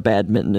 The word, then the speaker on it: badminton: And the pronunciation of badminton is so baffling. – Yeah. – I mean, it's really a hard word to, you're tempted to do the N and the T, the badminton.